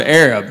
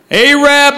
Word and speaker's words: Arab: It's spelled A-R-A-B. They don't pronounce it Arab. They pronounce it Arab. Because the original name was Arad, but they misspelled it on the water tower.